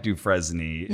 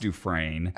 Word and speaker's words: Dufresne: It looks like Dufresne. It's pronounced Dufresne. – Okay. – And the reason I know that is, well, A, in Shawshank Redemption, the main character is Andy Dufresne.